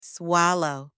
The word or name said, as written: swallow